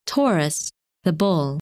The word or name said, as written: Taurus